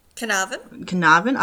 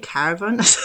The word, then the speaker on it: Carnarvon: So missing 4-year-old Cleo Smith has been found safe and well in a locked house in, I can never pronounce, is it? – Carnarvon. – I always get caravan.